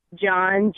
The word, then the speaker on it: John: John, J-O-H-N.